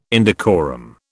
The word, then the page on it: indecorum: British English